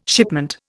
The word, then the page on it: shipment: Indian